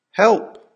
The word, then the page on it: help!: American English